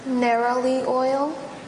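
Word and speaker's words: neroli oil: Narrowly oil? – Narrowly oil.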